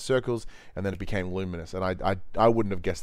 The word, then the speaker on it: Lumines: I think it was Lumines Arise. – Luminous. It's pronounced the Luminous.